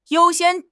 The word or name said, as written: 优先